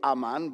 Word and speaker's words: aman: The actual literal definition of the Hebrew word, and it is a Hebrew word, and it's spelled A-A-M-A-N in the Hebrew, aman, but the anglicized version of that is amen, and it's been translated around the world.